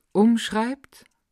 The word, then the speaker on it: umschreibt: Bin ich eine Sprache, die sich selbst umschreibt? Betonung auf der ersten Silbe bitte.